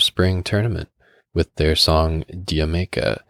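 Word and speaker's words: D'yer Mak'er: For those of you who are unaware, by the way, that's the Zeppelin song that the way that it's spelt, you would think it's pronounced Dire Mocker, but that's incorrect. It's in reference to some British joke about going to the islands. So it's you say it like Jamaica.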